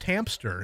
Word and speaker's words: hamster: But hamster is not pronounced hamster. It is hamster. No P on that.